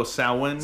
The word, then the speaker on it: Samhain: I heard there's a Celtic way to say that, you know, or Gaelic way, the proper way to say Samhain, you know? – Oh, Samhain.